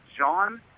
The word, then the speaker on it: John: My name is John, and she still pronounces it with a fucking Y.